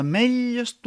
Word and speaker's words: ametlles: És la seva cosina germana, la ella geminada, que escrivim T-doble-L. És una ella reforçada, com dins les ametlles torrades, o encara la ratlla de França, un bitllet de tren. Escrivim una T, més se pronuncia pas. És just un codi visual per anunciar que cal doblar la pronunciació de la ella.